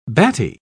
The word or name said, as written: batty